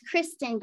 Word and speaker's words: Kristen: Am I saying your name right? It's Kristen? Uh, it's Kristen, but ev- it's okay.